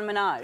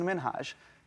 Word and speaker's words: Minhaj: everyone that says your name says Hasan Minaj. – Yeah, but the real way you pronounce it, and this is a big deal because my parents are here, it's Hasan Minhaj. And it, eh, people always mispronounce it. They're always like, Hasan Minajah, yeah. Hussein. It, I'm so sorry, I can't pronounce it. Meet my son, Higsby Witherthrottle yeah, the third.